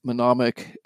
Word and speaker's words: mnemonic: And then when you get a disturbance, then you can start to, you know, go down through this mnemonic, how do you say that? – Mnemonic mm-hmm.